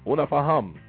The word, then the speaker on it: Unafahamu: Let me spell it first. U N A F A H A M U. But the U is silent. So it's not una fahamu. Una faham.